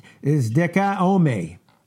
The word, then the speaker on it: dikaioma: Now, that word, justification, is dikaiome. Okay? And that's spelled D-I-K-A-I-O-M-A.